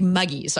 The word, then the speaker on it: muggies: But since I implicitly trust IRC the way that FICO trusts social media, I'm going to go ahead and make muggies our first MCLE passphrase for this episode of This Week in Law. Rhymes with huggies, folks, if you're concerned.